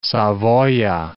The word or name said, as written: Savoia